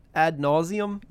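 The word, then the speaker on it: ad nauseam: Is it ad nauseum? – Yes. – Yes.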